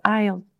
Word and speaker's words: ISLE: Donc IELE, ça s'écrit ISLE.